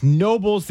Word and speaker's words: Knoebels: Greg in Bridgewater says it's called, the K is silent on Knoebels? – He says it's Nobles. The K is silent.